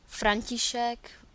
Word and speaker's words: František: Gabriela Frajová, Gabriela Frajová, František Brozan, František Brozan, and the T in František will be pronounced softly, so it's a T rather than T.